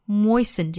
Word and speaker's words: moisten: 那今天的回学回用呢，我们来试着用 moisten 这个词来随意造一个句子啊。注意它的发音是 moisten, moisten.